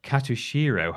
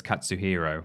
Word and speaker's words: Katsuhiro: On the PSN store, catching the attention of Tekken director Katsuhiro Harada. I think I said that right. Oh, no. It's Katsuhiro.